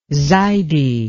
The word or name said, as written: zaidi